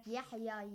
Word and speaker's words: Yahya: Like Yahya, ye is not pronounced.